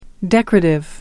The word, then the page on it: decorative: American English